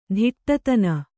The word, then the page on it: ತುಡುತಿನ್: kannada